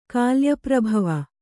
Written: ಕಾಲ್ಯಪ್ರಭವ

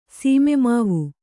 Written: ಸೀಮೆಮಾವು

